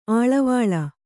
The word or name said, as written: ಅಳ್ಳೆವಿಳ್ಳೆ